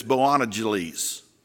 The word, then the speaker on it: Boanerges: It's called the son of, and I think it's pronounced, it's almost like Bojangles, but it's Boanerges. All right? So just think of Bojangles chicken.